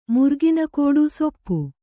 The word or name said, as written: ಸೂಪ